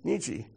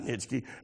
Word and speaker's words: Nietzsche: You don't pronounce Nitschke. It's like Nietzsche, right, or something like that? Nietzsche, not Nitschke.